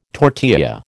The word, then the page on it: tortilla: American English